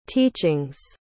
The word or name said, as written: teachings